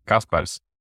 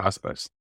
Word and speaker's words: Kaspars: Kaspers. – Caspar. – Kaspers.